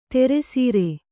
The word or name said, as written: ಧಾರಾಸಾರ